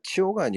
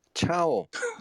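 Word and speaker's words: ciao: 저그 벨라 치오가 아니고 차오.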